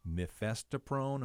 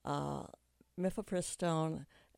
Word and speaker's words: mifepristone: The Supreme Court's been dealing with mifepristone.